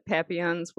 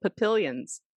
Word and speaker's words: papillons: You know, I used to think that papillons were called papillions.